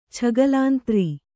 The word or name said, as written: ಛಗಲಾಂತ್ರಿ